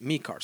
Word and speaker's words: Mieke: And thanks to Patrick and Mikar, because that's how he told me to pronounce it. His name is Mik, which is M-I-E-K-E, but he says pronounce it Mikar.